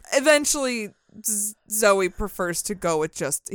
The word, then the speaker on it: Zowie: That May, Angie gave birth to their son, Duncan Zoe Haywood Jones, or Zoe for short. It was spelled like Bowie with a Z. – Like Zowie? – Yeah. Because I keep saying Zowie, but it was pronounced Zoe, like Bowie.